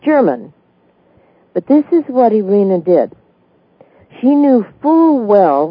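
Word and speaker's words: Irena: Irene was 98 years old when she died. I should say it's not Irene, it's Irina, or maybe it would be pronounced Irina.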